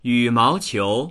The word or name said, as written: yu3mao2qiu2